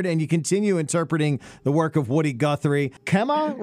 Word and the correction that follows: Okemah: Rising, is that how you pronounce it? Okemah. – Oh, Okemah. Oh, yeah.